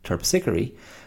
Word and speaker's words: Terpsichore: Terpsichore. Now at the, when I wrote it, I didn't realize it was pronounced that way. So I was pronouncing it Terpsichore, because Terpsichore, mispronunciation, actual pronunciation, Terpsichore, was the goddess of dance and choral singing.